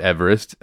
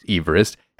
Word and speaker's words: Everest: It's not pronounced Everest. His name was pronounced Everest.